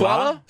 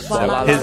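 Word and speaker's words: Swalla: You pronounce the la. – Oh, it's Swala. Not swallow? – Swala.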